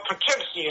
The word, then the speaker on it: Poughkeepsie: Yeah, at Poskipi, New York, in the Mid-Hudson. – Oh, you mean Poughkeepsie is pronounced.